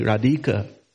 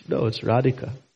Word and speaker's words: Radhika: That's like saying Radhika. It's Radhika. Surabhi. There's no log A over the... There's no light over the A. Surabhi. Surabhi abhipalayantam. That is, generally in English, we overemphasize the second syllable of words. And so sometimes we put that into Sanskrit. So we don't, most devotees, we don't say Radhika. We say Radhika because we know that one.